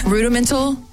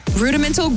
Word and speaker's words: Rudimental: Rudimental. Did I say that right? – Yeah. – Rudimental, Green and Gold.